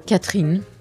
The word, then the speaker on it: Catherine: Est-ce que je prononce bien votre nom ? – Catherine. – Catherine. – Voilà, Catherine. Catherine, si vous voulez. – Non, je dirais Catherine.